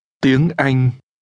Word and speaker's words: Tiếng Anh: Tiếng Anh.